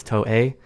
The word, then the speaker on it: Toei: It's spelled T-O-E, like toe, but it's Japanese, so it's Toei.